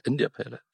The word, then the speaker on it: India Pale Ale: Isn't it India Pale Ale? – India Pale Ale, yeah.